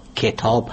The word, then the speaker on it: کتاب: به طور مثال تلفظ درست واژه کتاب، کتاب هست، یعنی با کسری کاف تلفظ میشه نه با فتح.